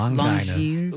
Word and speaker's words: Longines: Is there Longines? I don't know how you say it. – It's Longines.